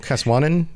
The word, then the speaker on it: Caswennan: Caswanan. Yeah. C A S W E N N A N.